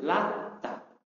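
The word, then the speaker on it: Allata (اللات): Allata itu bitakhfif ta. Itu taknya ditakhfif. Takhfif maksudnya apa? Tanpa tasdid. Ya. Allata. Nanti ada yang membacanya dengan tasdid, Lata.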